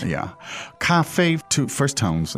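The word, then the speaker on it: Cafe (咖啡): How do you say the word coffee in Chinese, please? – Cafe. Cafe.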